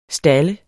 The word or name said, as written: STALDES